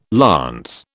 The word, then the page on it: lance: British English